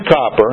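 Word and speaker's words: Cowper: William Copper, and that's how you pronounce his name, said this: acquaint thyself with God, if thou wouldst taste His works.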